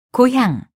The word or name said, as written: 고향